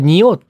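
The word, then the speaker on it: 仁王: これニオーって読むんだ。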